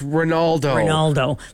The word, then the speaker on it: Ronaldo: Leonardo. – Am I saying it right? – It's Ronaldo.